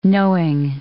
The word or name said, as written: knowing